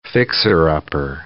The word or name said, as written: fixer-upper